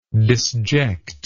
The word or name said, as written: disjection